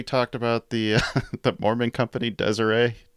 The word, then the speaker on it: Deseret: I think I corrected it to Deseret. No, it's Deseret. And for the third time now, Laura has corrected us. Deseret is the proper way to pronounce that.